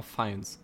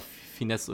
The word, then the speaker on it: Fiennes: Sagt, mein Englischlehrer sagt immer Fiennes. Ähm, ich nenne ihn immer Finesse oder so.